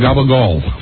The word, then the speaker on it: gabagool: It's called Gabagool.